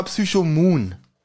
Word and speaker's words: Psychomoon: Also, erstmal hat er es falsch ausgesprochen, weil Psychomon, das klingt wie ein Pokémon. Ja, ja, das stimmt. Das heißt aber Psychomoon.